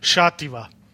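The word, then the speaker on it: Xàtiva: ja, tako da eh, tudi jaz sem najprej mislil, da je ksativa ali pa kot španci včasih ki x v nekaterih pokrajinah izgovorijo kot h, v nekaterih kot š ampak ker je to v tej isti pokrajini, kjer so dirkali danes v Valencijani, je potem šativa.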